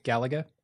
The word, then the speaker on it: Galaga: How do you pronounce it? – Galaga. – And I said Galaga? – Yeah. You put the emphasis on the wrong syllable.